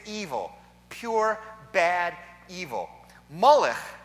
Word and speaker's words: Molech: From Melech, king, to Molech, shameful king.